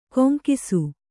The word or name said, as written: ಗುಂಗಿಸು